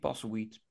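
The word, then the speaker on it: Postlethwaite: Is zijn naam, uh, Peter Passel. Zeg ik het goed? – Piet Passelweed. Passelweed, ja. ja.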